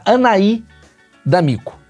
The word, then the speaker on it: Anahy D'Amico: Dona Anaí D'Amico.